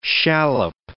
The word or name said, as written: shallop